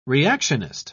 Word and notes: reactionist: British English

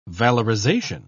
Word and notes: valorization: British English

